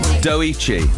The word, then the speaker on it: Doechii: And I like how you sent through the pronunciation of it. – I'm tired. I've had a lot of coffee. Doechii. Doechii. That's what I said.